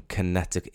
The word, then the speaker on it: Connecticut: Jake Kulak and his band are from Connecticut. It's Connecticut. Right, okay. Help me out with this. I would say Connecticut. Connecticut. On closer inspection, it's Connecticut. Surely you don't pronounce that middle C.